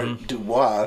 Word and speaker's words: Du Bois: Du Bois, or Dubois. – Dubois. – They were saying it incorrectly. – Yeah. – Forever. – He actually corrected motherfuckers and told them that it's Du Bois.